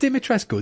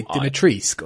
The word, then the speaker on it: Dimitrescu: Because didn't the host say Dimitrisk or something like that? – I don't know what. Yeah, I would say Dimitrescu just from football knowledge. – It might be a Romanian pronunciation thing that we don't know.